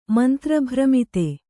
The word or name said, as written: ಮಂತ್ರಭ್ರಮಿತ